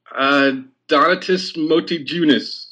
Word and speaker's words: Motiejunas: How would you say his name? – Donatus Motiejunas. Do I sound American? – It's pretty close. You know, I always was saying Motiejunas for some reason.